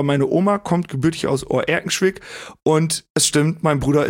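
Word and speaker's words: Oer-Erkenschwick: Ja, weil Recklinghausen liegt ja bei Oerkenschwick. – Ja. – Und ich glaube, ich habe das richtig gesagt. Sagen wir, Oerkenschwick liegt im Kreis Recklinghausen. – Ja. Ganz korrekt.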